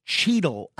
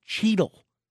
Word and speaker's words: Cheadle: The Cheetos brand erected the statue of a hand holding a massive Cheeto, complete with orange fingertips, in, I believe it's pronounced Cheadle, Alberta.